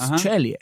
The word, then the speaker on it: Ceglie: Man schreibt das C-E-G-L-I-E. – Hast du einen Italiener? – Anscheinend schreibt man es italienisch aus. Cellie. – Cellie. – Chase Cellie.